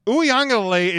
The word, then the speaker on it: Uiagalelei: Like, Uiagalelei is not – he's a 5-star recruit.